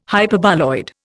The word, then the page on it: Hyperboloid: Indian